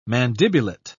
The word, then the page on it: mandibulate: British English